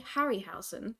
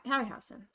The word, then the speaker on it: Harryhausen: I think it's pronounced Harryhausen. I read it as Harryhausen when I read it.